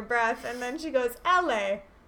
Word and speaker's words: Eli: Oh, I just looked at the IMDb, and it said, how is, Eli pronounced, and it said in the movie Eli is pronounced as one might pronounce the name Ellie.